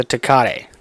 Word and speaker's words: Tecate: It's a Tecate. Is that how you say it? – Mm-hmm.